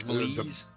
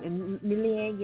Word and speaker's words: Méliès: No, seriously, a lot of George, what the hell, how do you pronounce his last name? Millier? George Méliès. – You got me. – Méliès, yes, the silent film producer, director guy, yeah.